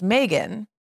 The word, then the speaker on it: Meagan: Now, one thing I also think is interesting is that my name was intended to be pronounced Megan. I think we've talked about this before. – But the extra A, I believe, was like my mom's way of putting her stamp on my name mm-hmm. to make it, you know, to make it pronounced differently than Megan.